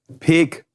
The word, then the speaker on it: Peek: Explizit Peek. Ja. Peek. Und da wird auch sehr viel Wert drauf gelegt in der Werbung, dass das so ausgesprochen wird.